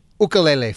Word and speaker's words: ukulele: Tell us about the New England Ukulele Festival. Am I saying it correctly, or is it ukulele? – You are saying it correctly. Bravo.